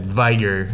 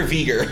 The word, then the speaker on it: vigor: That vigor, you know, the... – You managed to mispronounce that two different ways. Vigor.